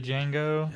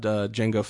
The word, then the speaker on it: Django: See, I was kind of disappointed because I thought it was going to be a spinoff movie about Django Fett. – Well, I mean, Are you sure that, you know, both Django's are spelled the same? I mean, we know in Django Unchained that the D is silent.